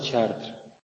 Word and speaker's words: Chartres: Bueno, yo seguro que sabéis cómo se escribe, pero yo lo pronuncio fatal. Pero es así, Chartres.